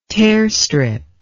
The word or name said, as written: tearstrip